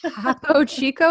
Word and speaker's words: Topo Chico: Now, there's been plenty more than three, but number one is kind of fun because I just showed you I was drinking Topo Chico.